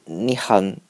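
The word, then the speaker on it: Nyhavn: So afterwards, we just hung out, we walked around the city, we went to the new harbor, the Nihon It's the Danish word is difficult to pronounce. It's pronounced like 你好 in Chinese, but it means new harbor, I think, which is like the icon place of Copenhagen with different color of the houses.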